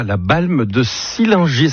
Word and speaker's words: La Balme de Sillingy: Et bon, ce début de semaine, on accueille Laurent avec ici, Pays de Savoie, la Balme de Sillingy. Est-ce que c'est comme ça qu'on prononce l'endroit où tu vis, Laurent? – C'est parfait.